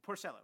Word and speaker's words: Porcello: His last name sounds like a kind of mushroom. Or like Italian food. Porcello. Rick Porcello.